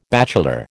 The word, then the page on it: bachelor: American English